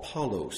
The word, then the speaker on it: Paulos: Paulos is actually how it's pronounced in Greek.